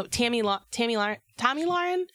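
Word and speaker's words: Tomi Lahren: So Tommy Lahren. – I believe that's the correct pronunciation.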